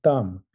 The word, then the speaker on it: Dan: So, and then the Lao Dan, the Dan part in modern Mandarin pronunciation, that's modern Mandarin pronunciation of both, that was pronounced Dam.